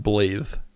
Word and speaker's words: Blaidd: So his name is B L A I D D, and it's pronounced Blathe.